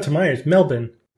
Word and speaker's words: Melbourne: You have not yet nailed the true Aussie pronunciation of Melbourne. You still have one too many vowels in there.